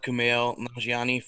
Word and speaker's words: Kumail Nanjiani: Yeah, I've been a fan of Kumail Nanjiani for a long time. I hope I'm saying that right. – I think you are. I think you're nailing it, dude.